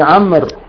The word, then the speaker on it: عمرو: ولما رواه أبو بكر، ولما رواه أبو بكر ابن محمد، أبو بكر ابن محمد ابن عمرو، عمرو كتب بالواو، قالوا داود تكتب بواو وحده، وعمر تكتب بواو، طبعا عمر أنا مرة نضع واو ولا تنطق، ترسم ولا تنطق،